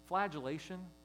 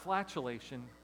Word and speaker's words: flagellation: Or especially, this is a word that is, flagellation. It's not flatulation, Caitlin. Flagellation. I'll spell that one for you too, Juliana. F-L-A-G-E-L-L-A-T-I-O-N. Okay, F-L-A-G-E-L-L-A-T-I-O-N.